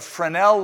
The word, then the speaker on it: Fresnel: So one scientist by the name of Fresnel, and his name is spelled F-R-E-S-N-E-L,